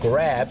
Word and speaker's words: grab: คำว่า grab ออกเสียง grab คล้ายๆกับกินกราบเนี่ยนะครับใส่ตัว g ตัว r แล้วก็ลากยาว grab